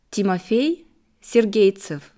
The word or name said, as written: Timofey Sergeytsev